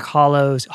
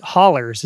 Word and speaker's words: hollers: Ruth Ann Music went around in these back hollows, hollers is how you say it.